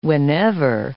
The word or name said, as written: whenever